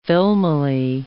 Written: filmily

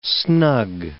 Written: snug

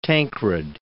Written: Tancred

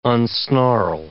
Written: unsnarl

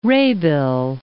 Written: Rebild

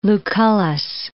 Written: Lucullus

